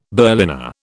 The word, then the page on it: Berliner: British English